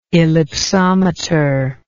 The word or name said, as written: ellipsometer